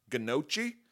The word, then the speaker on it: gnocchi: There was beet gnocchis. Gnocchis. Gnocchi. The G is silent.